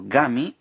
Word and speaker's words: origami: En Japón, el arte de la papiroflexia se conoce como origami. Ori viene del verbo oru, doblar, y kami, en este caso pronunciado gami, significa papel.